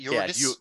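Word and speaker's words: Jurgis: But Jorgis is, I think, Jorgis. How you say it, right? Jorgis. – Jorgis. Yeah.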